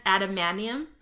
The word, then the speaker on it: adamantium: It's adamantium? – Yeah, it is.